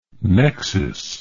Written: Nexis

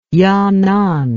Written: yanan

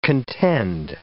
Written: contend

